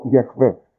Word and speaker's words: Γιαχβέ: Λοιπόν, οπότε, κατά προσέγγιση λέμε ότι πρέπει να προφέρεται το όνομα Γιαχβέ ή Ιεχωβά. Φαίνεται ότι καλύτερο πιο προσεγμένο είναι και πιο κοντινό είναι το Γιαχβέ και όχι το Ιεχωβά.